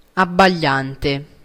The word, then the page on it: dazzling: [ˈdæz.lɪŋ]; American English